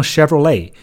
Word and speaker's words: Chevrolet: 它怎么读呢？读成 Chevrolet。那么 Chevrolet 本身最后那个 T 是不发音的，而且这个 E 的音也很诡异啊，它不是读成这种短音的 A，而是读成了一个这种元音的 A，这个 A 的音。